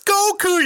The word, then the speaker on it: Goku: Did I say that right? – Son of Goku, yeah. – It's Goku, you silly bitch.